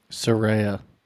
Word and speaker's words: Saraya: Saraya.